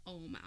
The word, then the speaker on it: Ogham: When I first heard of it, it's spelled O-G-H-A-M, so I call them Ogham. And yes. I remember going, what's the Ogham alphabet? But it's called the Ohm alphabet. It's just, you know, fuck phonetics, I guess.